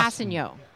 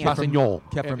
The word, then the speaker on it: Kpassagnon: Kpassagnon. Exactly. Tanoh Kpassagnon. Kpassagnon. If, if, you want to say the the proper pre- French pronunciation.